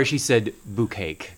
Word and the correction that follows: bukkake: So we had to correct her pronunciation and at least steer her in the vicinity of what that word meant.